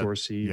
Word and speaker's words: Saoirse: Is it Saoirse Ronan? How do you fix this for me? – Yeah, I think it's Saoirse. I think that Saoirse, yeah.